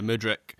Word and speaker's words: Mudryk: They're looking at what Mudryk is that the is that the right pronunciation for the the Ukrainian winger? Yeah, Mudryk. So Mudryk, he is he's a high price tag guy.